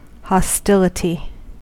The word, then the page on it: hostility: American English